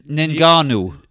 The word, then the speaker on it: Ngannou: It was Francis Netanyahu, Naganu, whatever. – How do you say his surname? – I used to know it, and like I said, it's silly. – Yeah. – Francis Ngannou. – Okay.